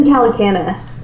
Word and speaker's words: Calacanis: I love that that Jason Calacanis.